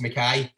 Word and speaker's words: McKay: McKay is spelled M-C-K-A-Y or spelled M-C-K-A-Y. You miss out the A, but it's usually they're both pronounced McKay, but It's easy, man.